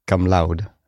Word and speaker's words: cum laude: It's not cum laude. It's cum laude.